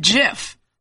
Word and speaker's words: GIF: And I know they asked him once, do you pronounce it GIF or JIF? And the guy said JIF. But that doesn't mean we have to say it like that. One day if I snap, it's probably going to be about this because graphic, graphic sounds like a G, which is probably why people assumed it would stand for graphic interchange formats and therefore be said GIF.